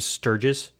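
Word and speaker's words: Sturgess: and they had other actors involved, but which were Jim Sturgis, Sturgis.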